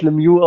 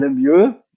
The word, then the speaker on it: Lemieux: Als alter NHL-Gucker verbinde ich mit dem Namen gut, den spricht man nicht Lemieux aus, sondern Lemieux.